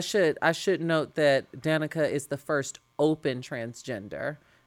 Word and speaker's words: Danica: So one of the shout outs this week um, goes to Danica Roem. Am I saying that correctly? – Yeah. Mm-hmm. Danica, though.